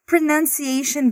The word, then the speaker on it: pronunciation: O nome dessa série aqui é Pronunciation Bootcamp. E esse U da palavra pronunciation é falado como a.